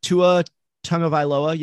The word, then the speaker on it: Tua Tagovailoa: if you don't get Tua Tagovailoa.